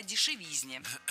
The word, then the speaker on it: дешевизне: Кстати, о дешевизне прозвучало не кстати, ведь в слове «дешевизна» ударение всегда падает на третий слог, поэтому должно быть «кстати о дешевизне».